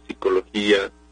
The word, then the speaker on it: psicología: Ahora bien, como cuesta mucho trabajo pronunciar esa P inicial, bueno, la fonética tiende a decir simplemente la psicología, pero es una variante también coloquial.